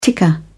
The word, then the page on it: ticker: British English